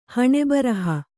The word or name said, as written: ಹಣೆಬರಹ